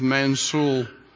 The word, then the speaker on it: Mansoul: The city was Mansoul, but it's spelt M-A-N-S-O-U-L, so Mansoul.